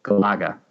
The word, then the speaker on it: Galaga: The correct pronunciation emphasizes the second syllable, Galaga.